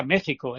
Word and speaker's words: México: Se pronunciaba como J. Imagínate, tú dices México, no dices México, ni dices México. Y sin embargo aparece ahí una X, ¿no? Se pronuncia México.